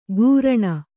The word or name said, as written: ಘೂರ್ಣ